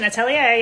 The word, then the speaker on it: atelier: Is that how you pronounce it? – Perfect pronunciation. Atelier, yes.